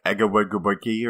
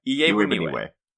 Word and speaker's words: Iebuniwe: Is it like Egawegubuki or something Yeah, no. But yeah. I had the pronunciation. Oh, here it is. Joel Iebuniwe, all right, out of Western Kentucky, the Hilltopper, is a guy who... – Say that name again? – Iebuniwe. – Iebuniwe. – Yeah.